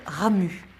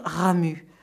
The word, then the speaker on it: Ramuz: Ramu. Ça s'écrit R-A-M-U-Z, mais on ne prononce pas le Z.